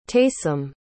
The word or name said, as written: taysom